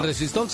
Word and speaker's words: Resistol: El resistol se llama así por el vocablo inglés Resist All. Se pronuncia Resistol, o sea que da a entender que dicho pegamento resiste todo.